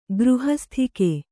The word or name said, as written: ಗೃಹಸ್ಥಿಕೆ